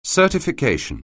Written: certification

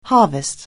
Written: harvest

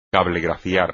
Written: cablegrafiar

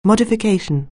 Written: modification

